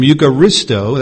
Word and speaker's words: eucharisto: Eucharistio, taken from eucharisto, that's spelled E-U-C-H-A-R-I-S-T-O.